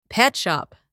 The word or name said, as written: petshop